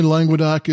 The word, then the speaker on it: Languedoc: Someone told me Languedoc is only two syllables, but to me, there's too many letters in there for only two, so I pronounce it with three.